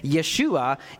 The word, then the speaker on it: Yeshua: So Joshua in Hebrew is basically the same thing as Jesus in Greek, or they would have pronounced it Yeshua in Hebrew.